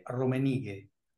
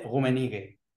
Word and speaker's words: Rummenigge: Eh, sí, parecido, en realidad, eh, eh, en español, digamos, como dice Roberto Fontana Rosa en su libro, sería Ute Romenigue. Ah. O, más bien, llevándolo al alemán como maneja el Ute Rummenigge.